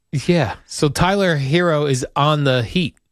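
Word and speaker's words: Herro: He appeared on WQAM radio and said, "Tyler Hero, is that how you say his name?" – Yes.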